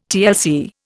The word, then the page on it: TLC: Indian